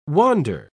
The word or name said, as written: wander